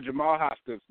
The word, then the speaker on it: Jamal Hoskins: Jamal Hoskins, man.